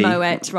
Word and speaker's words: Moët: Which is why you should always pronounce the T. – Moët rather than Moët.